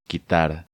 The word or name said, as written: Quitar